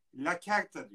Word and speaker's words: lacerta: Sonra e, Latince lacerta diyor. Bir kertenkele yani e, Latince La Certa diye okunuyor.